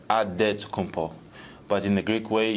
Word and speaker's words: Antetokounmpo: I've been led to say it's Antetokounmpo.